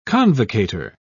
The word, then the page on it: convocator: British English